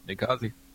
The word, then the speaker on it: Ninkasi: Yep, Ninkasi.